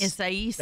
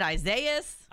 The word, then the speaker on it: Isaias: Isais. – That's how you say it? – Finally figured that one out. – Oh, holy cow. You were really messing that up. – I was calling it Isaias.